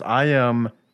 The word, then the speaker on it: IMDb: But how do you always say it wrong when you say it so often? It's IMDb. IMDb.